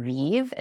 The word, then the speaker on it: Vive: And my handle is Dr. Vive, and Vive is spelled V-I-V-E, and that's all one word, Dr. Vive.